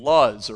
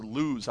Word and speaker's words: Luz: In fact, one author ties this to the name Luz, or Luz, however you want to pronounce that, L-U-Z.